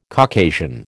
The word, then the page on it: Caucasian: American English